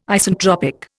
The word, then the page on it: Isentropic: Indian